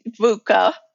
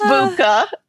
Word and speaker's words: VUCA: And you said it right, VUCA. Right. So sometimes people even stumble over VUCA, yeah. – So it is VUCA, which sounds harsh.